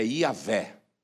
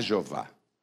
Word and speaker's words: Iavé: Os teólogos dizem que a pronúncia mais adequada para o nome de Deus é Iavé. Não é Jeová, é Iavé.